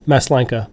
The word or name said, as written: Maslanka